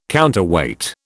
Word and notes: counterweight: British English

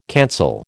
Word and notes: cancel: American English

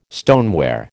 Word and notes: stoneware: American English

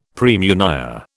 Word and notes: praemunire: British English

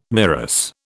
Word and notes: MIRAS: British English

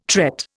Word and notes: tret: Indian

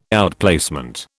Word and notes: outplacement: British English

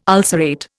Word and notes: ulcerate: Indian

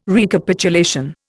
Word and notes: Recapitulation: Indian

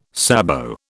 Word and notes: sabot: British English

